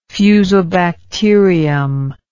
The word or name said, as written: fusobacterium